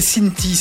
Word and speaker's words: Cinthie: Elle s'appelle Cinti, ça s'écrit C-I-N-T-H-I-E.